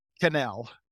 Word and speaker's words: Quesnel: Quesnel. – And that's Canel. Pronounced? – Canel. It's French Canadian.